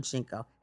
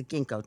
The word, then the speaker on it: ginkgo: And, you know, like after the is it pronounced ginkgo or jinko? I think it's the ginkgo tree. – Oh, yeah, ginkgo.